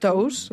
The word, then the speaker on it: Tous: Mai sé com es diu el cognom, perquè s'escriu Tous, per qui la busqui, sí? Però suposo que es pronuncia Tous. Com Míriam Tous. – Oi que sí?